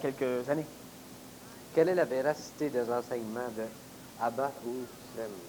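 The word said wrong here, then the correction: Abd-ru-shin: C'est mal prononcé. – Abrouchine.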